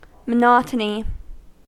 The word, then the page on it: monotony: American English